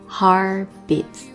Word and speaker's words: beats: 首先来看到第一小段歌词 Heart beats fast， Heart 失去爆破， Beats 字母 TS 组合的发音注意一下， Heart beats 不要读 beats， Heart beats， Heart beats fast，后面这个 T 轻轻的， Heart beats fast。